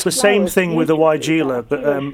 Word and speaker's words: Weigela: And the other one was, I don't know how to pronounce this, is my sister-in-law used to call it Weigela. It's Weigelia. – Weigelia, yep.